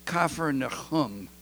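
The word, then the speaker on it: Kfar Nahum: Jesus lived with his leading disciple, Simon Peter, and Simon Peter's mother-in-law, in a little home in Capernaum. I don't know how they say it in Hebrew. Kafar Nahum, the village of the prophet Nahum, and Capernaum. And it's right beside the lake, the Sea of Galilee.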